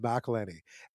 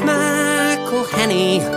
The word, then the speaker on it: McElhenney: It's McElhenney, McElhenney. What ways to massacre and mispronounce it, there are many. – McElhenney.